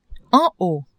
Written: en haut

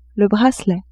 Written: bracelet

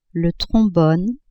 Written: trombone

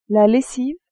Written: lessive

